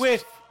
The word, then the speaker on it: Whiff: I sniffed the microphone then, thinking it was Spunky Whiff. But the H is pronounced, so it's Spunky Whiff.